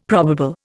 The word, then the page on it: probable: Indian